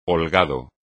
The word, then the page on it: holgado: espagnol